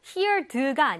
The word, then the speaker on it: heard: 자, 발음도 heard가 아니라 heard입니다.